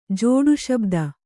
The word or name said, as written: ಜೋಡುಶಬ್ದ